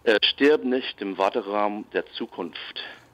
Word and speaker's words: Stirb nicht im Warteraum der Zukunft: Stirb nicht im Warteraum der Zukunft,